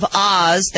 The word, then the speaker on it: Ahs: The Land of Oz, that is spelled A-H-S, as in, oh, what a cute baby, now has its own pet limo complete with curtains so no one can see who's really inside.